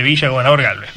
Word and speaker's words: Villa Gobernador Gálvez: Recordemos que Luis Domínguez es secretario general del Sindicato de Trabajadores Municipales Independientes de Villa Gobernador Gálvez. ¿Lo dije bien? – Perfecto. Así es, perfecto.